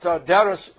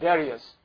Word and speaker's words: Darius: If you look into our Bible, we read the way it is, we call it Darius. Alright? But it's Darius. And sometimes we pronounce it the way we read in the Bible. So if you want to say King Darius, the Median, that's fine. But if you want to say it's Darius, as in the way that it should be pronounced in their language, their name.